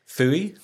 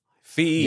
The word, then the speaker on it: Feuilly: What, the P-H-I-E... – No, it's F-E-U. – I was so far off. – Yeah, way off. – Do F-E-U-I-L-L-Y. – That's it, yeah. Fee, yeah. I think is how you say it.